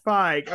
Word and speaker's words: Feig: Feig, okay.